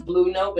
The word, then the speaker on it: BLou Note: My name is Blue Note, but it's not spelled like the color, ironically. And it's also not my favorite color, even though I've kind of adopted it. It's just funny, but Blue is spelled BLOU and then NOTE.